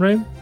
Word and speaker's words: reign: No, I think we've R-E-I-G. N? Is that how you spell it? Rain? – Rain. – Yes. – Sure. – Right? – Silent G, yeah.